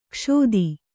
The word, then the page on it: ಕ್ಷೋದಿ: kṣōdi